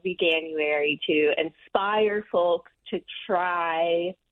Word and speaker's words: Veganuary: And I think people pronounce it veganuary, as if you would say January, even though it's not how you say vegan.